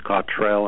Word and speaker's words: Cottrell: My name is Douglas James Cottrell, and Cottrell is spelled C-O-T-T-R-E-L-L.